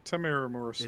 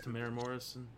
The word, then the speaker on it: Temuera Morrison: Temuera Morrison. – Is it just Temuera Morrison? Okay. – Yeah.